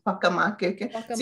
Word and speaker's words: faka makeke: So F-A-K-A. M-A-K-E-K-E. Haka makeke. See, we pronounce the F like hua, like I know, right?